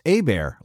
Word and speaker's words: Hebert: My name is Kyle Hebert, spelled H-E-B-E-R-T, but it's pronounced Hebert, like a bear is attacking me.